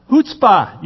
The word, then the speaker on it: chutzpah: Chutzpah. You don't say the CH there. You say chutzpah.